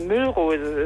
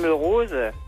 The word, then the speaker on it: Müllrose: Ja, und diese Antennehörerin echauffiert sich darüber, wie man Mörose falsch aussprechen kann. Manche betonen wohl dann doch mal die erste Silbe. Das ist natürlich falsch. – Das ist doch keine Müllrose, das ist Mörose.